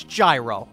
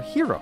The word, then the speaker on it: gyro: It's pronounced the way it's spelled. It's gyro. Enough with the fancy schmancy. We're not in Greece. – It's properly pronounced hero.